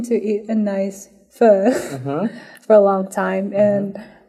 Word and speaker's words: pho: Excuse me if I like pronounce this wrong, but I've been like wanting to eat a nice, you know, pho. Okay. Is it okay, Fa? – Yeah. So the right pronunciation is really difficult. It's like pho.